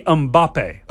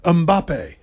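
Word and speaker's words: Mbappé: So how am I pronouncing it again? – It should be Mbappe. – Mbappe. Okay. Mbappe.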